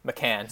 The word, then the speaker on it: McCann: Acquired a 2023 seventh rounder and Philip Hollander for Jared McCann, who... McCann, sorry. I don't know why I pronounced that like the Porsche car.